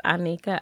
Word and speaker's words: Onnikah: Feel free to follow me phonetically on Instagram as it's Onnikah, I-T-S-A-H-N-E-E-K-A-H, because all some right. people cannot pronounce, you know, when they see it, O-N-N-I-K-A-H.